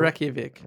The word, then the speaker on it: Reykjavik: Reykjavik.